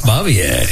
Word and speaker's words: Bavier: Frances Bavier. – Frances yep. Bavier. – I was never really sure how to pronounce it, but that's correct.